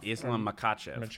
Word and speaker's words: Islam Makhachev: Islam Makhachev.